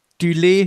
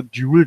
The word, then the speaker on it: Dulé: Ich glaube, die Betonung ist über dem E. Eh, ja. Ich habe immer mal Duel gesagt, wenn ich das gelesen habe, so im Kopf. Duel Hill.